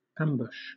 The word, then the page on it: ambush: British English